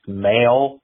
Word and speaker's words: M(h)aol: They spell their name M-H-A-O-L. The H is in parentheses. Apparently it's pronounced male.